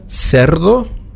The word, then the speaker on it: cerdo: Cerdo, das wäre CE. Für Schwein wäre die Übersetzung Cerdo. Cien kennt jeder, 100. Das wäre auch ein Beispiel für CI. Cien, beide Male ausgesprochen wie ein S.